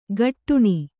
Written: ಗಟ್ಟುಣಿ

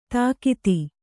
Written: ತಾಕತ್ತು

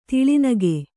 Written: ತೌಲನಿಕ

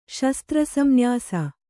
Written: ಶಸ್ತ್ರಸಂನ್ಯಾಸ

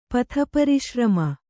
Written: ಪಥಪರಿಶ್ರಮ

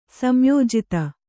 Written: ಸಂಯೋಜಿತ

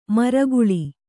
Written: ಮರಗಾಳಿ

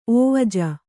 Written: ಒವಜ

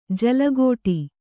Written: ಜಲಘಡಿ

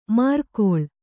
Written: ಮಾರ್ಕೊಳ್

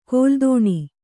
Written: ಕಲ್ದೋಣಿ

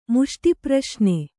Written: ಮುಷ್ಟಿಪ್ರಶ್ನೆ

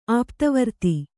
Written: ಆಪ್ತವರ್ತಿ

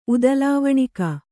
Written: ಉದಲಾವಣಿಕ

